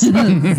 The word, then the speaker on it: Zappos: Pitbull is performing at Zappos Theater. Wow, what is happening with my inflections? Zappos. I put the wrong emphasis on the wrong syllable.